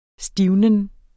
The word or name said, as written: stivnen